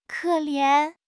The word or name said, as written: ke3 lian2